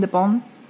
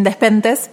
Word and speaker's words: Despentes: El otro día leía a Virgin de Pont, nosotros lo conocemos como Virgin Despentes, pero de Pont es en realidad como se pronuncia correctamente su, apellido en francés, y ella tiraba un concepto que me pareció muy interesante.